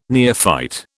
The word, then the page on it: neophyte: British English